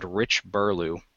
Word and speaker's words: Rich Burlew: it's just a really cool comic online by a man named Rich Burlew. I think I pronounced that right. B-U-R-L-E-W. – I believe so.